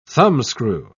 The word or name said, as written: thumbscrew